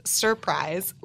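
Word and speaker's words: 5urprise: So it's a group that is technically pronounced Surprise. We call them Five Prize or Fur Prize because it's spelled with the number five instead of a capital S. So it's just Surprise, but it's a five, U-R-P-R-I-S-E.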